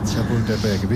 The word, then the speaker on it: Chapultepec: Chapultepec. Mejor que algún cementerio francés. – ¿Iglesias? Chapultepec, bien, bien, bien.